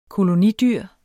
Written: kolonidyr

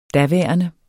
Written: daværende

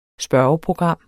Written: spørgeprogram

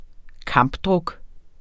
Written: kampdruk

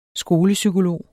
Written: skolepsykolog

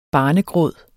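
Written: Barnegråden

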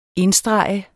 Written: INDSTREGET

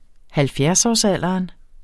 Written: halvfjerdsårsalderen

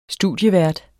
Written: studievært

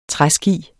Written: træski

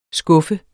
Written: skuffe

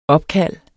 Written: opkald

